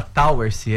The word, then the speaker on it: Towersey: Se escreve Towersey, mas se fala Towersey, é inglês.